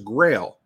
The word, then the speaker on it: Grayl: Close, it was Grail, but it's spelled G-R-A-Y-L, and it is an awesome water filter.